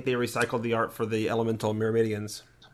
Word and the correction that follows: Myrmidons: The Myrmidons? – Yeah, that's it.